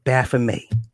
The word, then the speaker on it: Baphomet: He has to dip his hand in the blood of Baphomet. Wait a minute. Let me stop you right there. Baphomet in this movie is given the French pronunciation. But if you look it up, it's spelled with the T, okay?